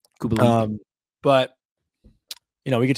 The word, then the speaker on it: Kubalik: Kubalik. – Is that how you say it? Kubalik? – Yeah.